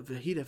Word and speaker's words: fajita: Light sour cream, light cheese, and the fajita veggies. Did I say that right? – Yeah.